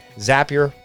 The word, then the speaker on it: Zapier: Zapier rhymes with happier, and it makes my work life happier, and it'll do the same for you.